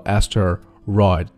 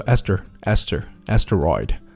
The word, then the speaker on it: asteroid: 下面是今天的读音指导。New data revealed that the interstellar asteroid, 注意中间那个 t, 它的音变啊，那就不用读作 asteroid, 读作 asteroid,